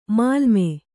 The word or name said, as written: ಮೇಲ್ಮೈ